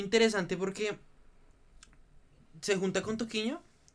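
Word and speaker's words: Toquinho: Toquiño.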